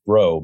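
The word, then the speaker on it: Breault: Mark, it's actually pronounced Bro.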